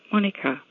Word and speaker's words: Monika: In the German language, my first name is spelt with a K and is pronounced Monika.